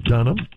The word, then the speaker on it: Dunham: Dunham. – And how do you spell that? – D-U-N-H-A-M.